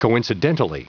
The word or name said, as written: coincidentally